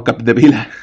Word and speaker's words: Capdevila: Roser Capedilla, perdón, Capdevila.